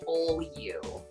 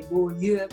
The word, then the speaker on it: Beaulieu: I just give it an English pronunciation because it's easier. I just say Beaulieu. – Beaulieu. Is it French? – It is, yeah. So if I was French, I would say Beaulieu, but it sounds odd coming out of my mouth, so I just say Beaulieu.